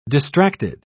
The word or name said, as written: distracted